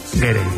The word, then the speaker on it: Gere: estaba pensando yo que si te apellidas Ger, bueno, ¿cómo se dice Ger o Gerre? Gerre.